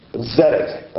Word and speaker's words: Zedek: It's hard to do it because if you say it in Hebrew and you're looking at the English, I want to pronounce it in Hebrew. It's Zedek, okay, the very end.